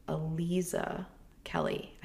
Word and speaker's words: Aliza Kelly: Okay. Aliza Kelly. I said it right.